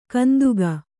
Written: ಕಂತೆಗ

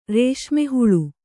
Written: ರೇಷ್ಮೆಹುಳು

